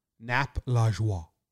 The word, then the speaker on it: Lajoie: Nap Lajoie.